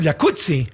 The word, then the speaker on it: jacuzzi: Entonces deberíamos pronunciar el jacuzzi. – Exacto. – Como pizza. Como rampazzo, como Claudia Rampazzo.